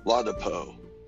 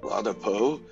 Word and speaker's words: Ladapo: Florida Surgeon General released a shocking report on Friday, according to Dr. Joseph Lapado. – Did I say that right? – Ladapo. Oh, see, that dyslexia kicked in.